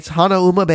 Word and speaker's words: Hanauma: Now, Hanauma Bay, or Hanauma Bay, however you want to say it, Hanauma Bay is the correct pronunciation.